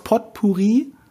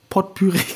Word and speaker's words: Potpourri: Dass ich aus Potpourri Potpüree gemacht habe.